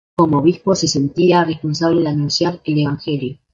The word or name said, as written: responsable